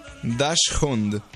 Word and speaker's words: Dachshund: Dachshund.